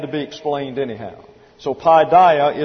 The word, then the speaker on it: paideia: P-A-I-D-E-I-A, pronounced paideia, and it means the corrective discipline of chastisement.